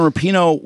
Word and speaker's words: Rapinoe: When Carl said Megan Rapinoe was at the, did I say her name right? Yeah, Rapinoe.